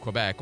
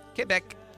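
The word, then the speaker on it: Québec: Finally, a quiet, trophy-free moment of appreciation for the groundbreaking work done recently in the Canadian province of Quebec, or as the locals say it, Quebec, by Superior Court Judge Andres Guérin, who finally handed a group of non-traditional families equal justice before the law.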